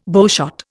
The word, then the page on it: bowshot: Indian